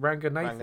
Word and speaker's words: Ranganathan: Ranganathan? – Ranganathan, yeah.